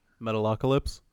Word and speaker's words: Metalocalypse: It's like Metalocalypse. Did I say that right? – Metalocalypse? – Yes, I did say that right.